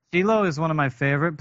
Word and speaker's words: Shiloh: One thing that I've always pronounced it Shiloh, but I found out when we got there, it's actually called Shiloh.